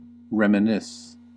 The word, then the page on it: reminisce: American English